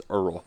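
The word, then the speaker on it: Erroll: Is Earl Thompson. – Two R's. – Rhymes with Merle.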